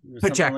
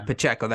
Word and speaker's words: Pacheco: He got some big receptions down, and then they went with Pacheco. I think that's how it's pronounced. Their running back. – They have Pacheco or Pacheco. Like that. Yeah, Pacheco. That sounds right.